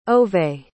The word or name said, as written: Ove